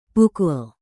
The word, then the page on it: pukul: indonesian